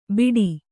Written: ಬೀಡಿ

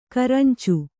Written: ಕರಂಚು

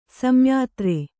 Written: ಸಂಯಾತ್ರೆ

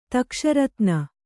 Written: ತಕ್ಷರತ್ನ

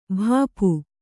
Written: ಭಾಪು

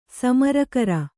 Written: ಸಮರಾಕರ

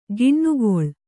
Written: ಕೆನೆಗೊಳ್

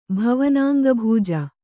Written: ಭವನಾಂಗಭೂಜ

